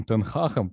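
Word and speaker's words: ten Hag: А, повторюсь еще раз, надеюсь, что новая эра Манчестер Юнайтед с Эриком Тенхахом, кто не в курсе, именно так произносится его фамилия, вот, а, на нидерландский манер,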